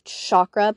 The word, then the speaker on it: chakra: chakra because every chakra is different. Also, I just want to point out, whether you call it chakra or chakra, it doesn't really matter. I've heard it pronounced both ways, so don't feel like you're wrong if you've been saying it a different way from the way I pronounce it.